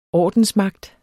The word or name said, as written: ORDENSMAGTEN